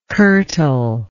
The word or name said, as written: curtal